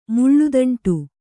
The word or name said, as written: ಮುಳ್ಳುದಂಟು